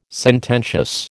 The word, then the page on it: sententious: American English